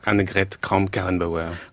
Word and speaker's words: Annegret Kramp-Karrenbauer: Annegret Kramp-Karrenbauer. – Bravo, vous l'avez bien prononcé.